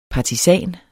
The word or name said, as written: partisan